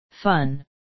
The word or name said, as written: fun